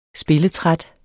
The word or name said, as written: spilletræt